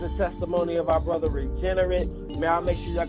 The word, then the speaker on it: Regenerit: Yeah, basically Regenerit on all social media, which is spelled R-E-G-E-N-E-R-I-T. The reason for the spelling is the like Regenerate and Regenerit are spelled exactly the same way. So just to let just think how it's pronounced is how it's spelled. Regenerit, R-E-G-E-N-E-R-I-T.